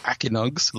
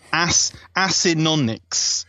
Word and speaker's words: Acinonyx: So you don't just say Akinogs, like you're some kind of Akinogs.